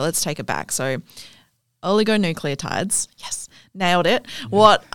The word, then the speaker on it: oligonucleotides: You've definitely, gave your best shot. Oligonucleotides indeed is the best pronunciation.